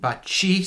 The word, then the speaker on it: baci: So CA is a ka, and baci, CI is a chi.